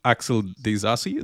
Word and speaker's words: Axel Disasi: Axel Disasi, that's how you say his name.